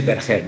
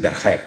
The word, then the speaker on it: Berger: In Spanish, we say Berger. My great grandfather was French. – Mm-hmm. – But in Spanish, it's Berger. But every country pronounce my last name, so it doesn't matter.